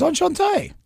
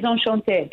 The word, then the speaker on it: enchanté: How we pronounce enchanté, is that it? – It's enchanté. – It is enchanté, exactly.